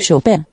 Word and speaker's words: Chopin: So I was right, Chopin. Motherfucker. – How about that for once? I was right. – Chopin.